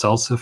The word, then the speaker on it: SLSA: It's written S-L-S-A and it's pronounced SALSA for some weird reason.